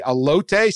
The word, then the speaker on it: elote: How did I say it? – Elote.